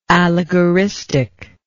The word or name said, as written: allegoristic